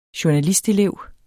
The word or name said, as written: journalistelev